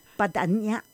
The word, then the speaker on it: Badagnani: But then it should be Badagnani. – That's exactly it. The G is silent. Like exactly. – Lasagna. – Like, and Modigliani.